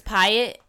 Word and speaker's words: Pyatt: Oh, at Miss Pyatt, MS.PYATT, like the Hyatt Hotel, but with a P.